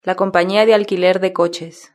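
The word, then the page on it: la compania de alquiler de coches: Spanish (Spain)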